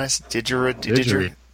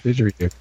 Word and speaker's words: didgeridoo: I said that wrong again, didn't I? Didgeridoo. – No, that's right. – Okay. – Didgeridoo.